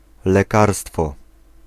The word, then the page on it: lekarstwo: [lɛˈkarstfɔ]